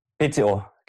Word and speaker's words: PCO: Wie spricht man die Firma aus? PCO. – PCO, genau. Ja.